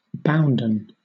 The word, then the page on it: bounden: British English